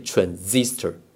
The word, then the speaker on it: transistor: 念 transistor 啊，transistor 那个是念 z 的音。